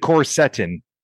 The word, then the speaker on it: quercetin: You pronounce it quercetin. Did I get that right? – Quercetin.